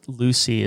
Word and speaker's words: Lucea: You said Lucy. Is that L-U-C-Y or is there a different spelling to that? – Yeah, it's spelled L-U-C-E-A and okay. It's pronounced Lucy.